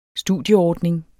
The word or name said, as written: studieordning